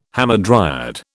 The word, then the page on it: hamadryad: British English